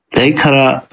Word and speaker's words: Leikara: But I believe it is pronounced Leikara Ljus. It is two words. The first word is spelt L-E-I-K-A-R-A, so something along the lines of Leikara. And then the second word is L-J-O with, I don't remember the type of accent this is.